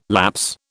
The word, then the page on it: lapse: British English